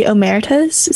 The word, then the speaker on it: Omertas: And I want to start off um, introducing the the Omertas. Is that how you pronounce that? – Yeah, I think so.